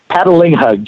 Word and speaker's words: Patalinghug: Patalinghug, just like it's spelled. And it sounds like paddling somebody and then hugging them.